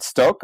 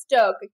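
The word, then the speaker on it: Stokke: is it Stoke or Stock? – Yeah, you know, I think it's pronounced Stoke.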